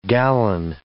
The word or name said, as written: gallon